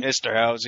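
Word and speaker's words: Eszterhas: But Joe Esterhaus, am I pronouncing that properly? – Esterhaus, yeah.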